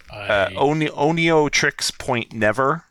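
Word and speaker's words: Oneohtrix Point Never: The music is I don't think it's all written by, but it was at least supervised by I never know how to say his name, Onio Point Matrix One. Oh God, I butchered that. Onio Trix Point Never, rather, otherwise known as his name Daniel Lopatin.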